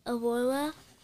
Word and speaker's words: Aurora: have to go through what Aurora, I don't know how to say her name. – Aurora story have to happen.